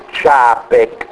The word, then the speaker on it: Čapek: La grande idea della fantascienza e di Čapek per primo, allora Čapek si scrive così, Karel, che è come dire Carlo, Čapek, perché nelle lingue slave in genere la C si legge Z e la C si legge C solo quando c'è sto cazzillo sopra, Allora, questo si legge Čapek, se non c'avesse il cazzillo sopra si leggerebbe Čapek, vero?